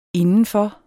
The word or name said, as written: indenfor